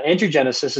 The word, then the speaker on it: angiogenesis: I totally agree okay angiogenesis i'm saying that right Angiogenesis. – Angiogenesis.